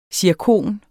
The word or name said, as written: zirkon